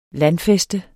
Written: landfæste

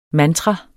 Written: mantra

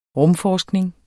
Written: rumforskning

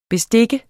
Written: bestik

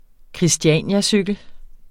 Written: christianiacykel

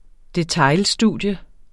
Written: detailstudie

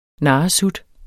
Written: narresut

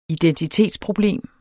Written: identitetsproblem

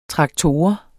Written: traktor

